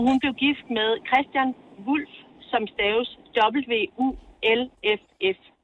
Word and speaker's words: Volf: Så da man under krigen ligesom gerne vil være mere dansk, så ændrede de faktisk navn til at hedde V-O-L-F, og det udtales Wolf.